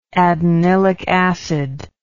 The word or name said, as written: adenylic acid